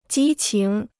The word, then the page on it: 激情: jī qíng